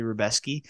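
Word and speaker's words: Rebecki: It looks like Rebecca, but it's actually Rebeski um, over Roosevelt Roberts, got, subbed him in the first round.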